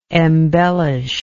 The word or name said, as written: embellisher